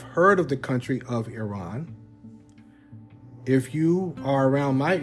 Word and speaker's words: Iran: Today, we're going to look at the country of Iran, or Iran, as it should be pronounced, Iran, but many of us say Iran, either one.